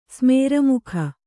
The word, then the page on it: ಸ್ಮೇರಮುಖ: kannada